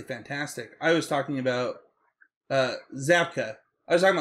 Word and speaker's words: Zabka: I think a huge part of that is Zappo and or it's not Zappo. What's I just said his name wrong.